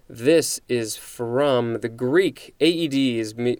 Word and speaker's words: Aedes: It is spelled A E D E S. It looks like the A and the E are both separate syllables, so this is Aedes.